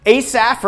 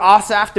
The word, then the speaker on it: Asaph: Asaph or Asaph, depending on where you grew up and how you pronounce this, was another member of David's court that was specifically charged with writing praise music for the people.